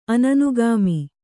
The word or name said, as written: ಅನನುಗಾಮಿ